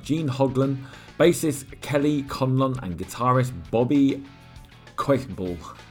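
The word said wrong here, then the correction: Koelble: That is a tough one to pronounce. Bobby Colball.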